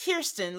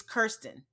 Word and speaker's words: Kirsten: It's like Kerr, like fur. So it's Kirsten. Not Kirsten, like we've been saying. It's Kirsten.